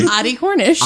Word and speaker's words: Audie Cornish: Abby Cornish. Or whatever you said. I don't even think I said that right. – Audie Cornish. – Audie. – Like the car, Audie? – I don't know. I've never seen it Audi. Spelled. – You could say Audie. – It could be Audie, like O-T-T-I-E.